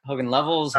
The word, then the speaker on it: Hogan Lovells: My guest today is a partner with Hogan Lovells in Los Angeles. Am I pronouncing that right? Hogan Lovells? – Hogan Lovells. Yeah. – Hogan Lovells. Okay.